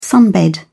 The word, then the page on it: sunbed: British English